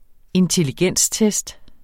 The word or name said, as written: intelligenstest